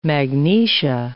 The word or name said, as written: magnesia